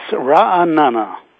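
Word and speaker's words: Ra'anana (רעננה): And please, because I am concerned about pronouncing it correctly, would you pronounce the name of the city you're in? – It's Ra'anana. – Ra'anana.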